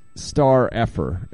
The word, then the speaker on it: STRFKR: That is It's Star Effer is the way they pronounce their they write their name like with no vowel, so it's like S T R F K R.